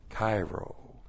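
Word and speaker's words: kairo (χαίρω): First of all, we have the verb kairo. Looks like this in the Greek. That's C-H-A-I-R long O, kairo.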